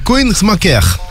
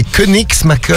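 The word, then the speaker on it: Koenigsmacker: Alors, Koenigsmacher. – Koenigsmacher. – Voilà, en Moselle, bref. – C'est Koenigsmacher.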